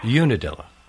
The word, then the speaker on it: Unadilla: How big is, am I pronouncing it right, Unadilla, Georgia? – Unadilla.